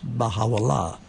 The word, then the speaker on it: Baha'u'llah: And in that language of Arabic, it's pronounced Baha'u'llah.